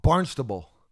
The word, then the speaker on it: Barnstable: No, I just said that you were pronouncing it wrong. – Barnstable.